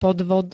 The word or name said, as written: podvod